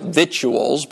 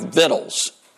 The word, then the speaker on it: victuals: And I've heard people try to be proper and pronounce it victuals, but that's not the way it's pronounced, victuals. That's the way you literally pronounce it. Scorby, Alexander Scorby says it that way. Everybody says it that way. Granny says it that way on the Beverly Hillbillies, victuals.